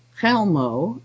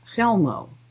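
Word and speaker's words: Chełmno: I discovered that is the pronunciation, is silent C. I was thinking it was like a K sound, but sometimes I wasn't sure, so I used a CH sound, but it's just an H sound, but Chelmo, are practiced exponents of this method of constantly bringing out newly created distortions.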